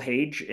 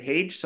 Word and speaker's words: Hage: Hage, often mispronounced as Hag, but ran into his mother recently and she pronounced it Hage. So we're going to go with Hage.